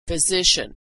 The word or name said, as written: physician